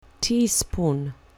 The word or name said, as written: teaspoon